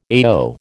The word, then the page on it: AO: American English